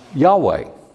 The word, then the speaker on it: Yahweh: God's name in the Hebrew text of Scripture is comprised of four Hebrew letters, the Yod, the He, the Vav, and the He, which would be pronounced Yahweh.